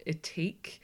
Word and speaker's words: Ethique: She was the founder of Ethique. – Oh, is that how you say it? – That's how you say it.